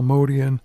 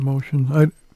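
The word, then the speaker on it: Motian: I heard Bill Evans calling Paul Modian. Usually I've heard people pronounce it Motion. It's interesting because he played with him. He probably would know something.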